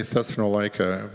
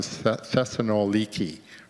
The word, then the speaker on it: Thessaloniki: But the first place that we went to was in English, I guess we say Thessalonica, but they pronounce it Thessaloniki or something like that.